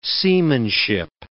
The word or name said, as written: seamanship